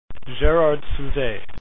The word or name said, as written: souzay